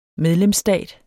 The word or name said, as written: medlemsstat